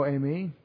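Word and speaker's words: eimi (εἰμί): Ego eimi, which is E-G-O. E-I-M-I. Some of you think Amy says I-me, but E-I doesn't say I, it says A, or ask me in pronunciation, and probably modern Greek or classical Greek pronunciation too.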